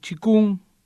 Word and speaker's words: Qigong: Y hoy vamos a hablar del Qigong, o como se pronuncia en chino, Qi, que es energía, y Kong, que es trabajo.